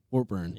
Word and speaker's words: Warburton: Warburton.